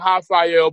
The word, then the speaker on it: Rafael: And I'm saying Rafael, but it's spelled with an R. That's how Brazilian names are pronounced. The R, it has an H sound.